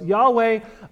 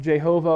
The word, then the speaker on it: Yahweh: Yahweh, I believe, is a close pronunciation of the tetragrammaton of the Hebrew four-letter words. We don't have vowels, or they don't have vowels in Hebrew. Some people believe it's pronounced Jehovah, or that's probably traditionally what you refer to it as in the King James Bible.